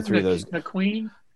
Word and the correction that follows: Naquin: Ch- Tyler, Tyler Na- McQueen guy. – Tyler Naquin, I think is how it's pronounced.